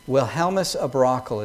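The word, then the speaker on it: Wilhelmus à Brakel: The Synod of Dort, the Westminster Assembly, and then countless other Puritans and pastors of the day and theologians, Francis Turretin, Wilhelmus Abrakel, is that pronounced correctly? Yeah, same position.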